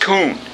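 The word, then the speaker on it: Kun (כּוּן): And it is spelled in Hebrew as K-U-W-N, pronounced Kun.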